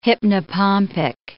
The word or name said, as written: hypnopompic